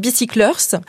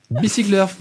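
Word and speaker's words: Bicycle'Earth: Alors on le dit à la française, hein, parce que c'est un petit jeu de mots. Effectivement, c'est Bicyclers.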